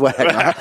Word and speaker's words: Wagner: We've been pronouncing it Wagner. I'm like, no, it is Wagner. Just taking the mickey.